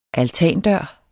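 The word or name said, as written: ALTANDØR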